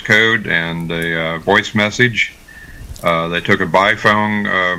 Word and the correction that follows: Baofeng: How do you pronounce that, Alan? The B-A-O-F-E-N-G. – Baofeng radio. – Baofeng. Yeah.